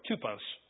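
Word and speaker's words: typos (τύπος): They pronounce it different, but it's the same word, typos. T-Y-P-O-S, typos.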